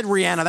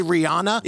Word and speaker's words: Rihanna: And yeah, I said Rihanna. That's the proper way to say it. I know a lot of people say Rihanna. It's Rihanna. Okay, that's how she likes to be referred to.